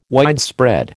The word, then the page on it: widespread: American English